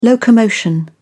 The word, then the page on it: locomotion: British English